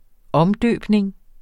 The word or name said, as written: omdøbning